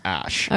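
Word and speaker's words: æ: he said it's pronounced ash, that word is pronounced, that letter is pronounced ash, the A-E is pronounced ash.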